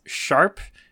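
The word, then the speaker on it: ♯: Sharp sign, huh? – Yeah, so. – Wow. It's not pound.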